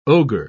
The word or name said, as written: ogre